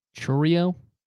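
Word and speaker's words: Chourio: So yeah, I'll take Jackson Chororo as rookie of the year. – How do you pronounce this last? I botched it. So Chororo, like a churro.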